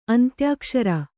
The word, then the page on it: ಅಂತ್ಯಾಕ್ಷರ: antyākṣara